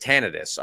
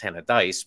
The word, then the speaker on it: Tannadice: And I said, Tannadice. I was worried that I was mispronouncing it. As it turns out, I was, because it's Tannadice, but that's neither here nor there.